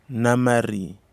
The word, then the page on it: namari: Kwamera